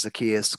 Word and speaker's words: Zacchaeus: That's how you say it. That's right. Thank you, someone who's actually read the Bible before to pronounce the biblical names. I'm like, Zacchaeus. – It's Zacchaeus. He's the one from the, I know him.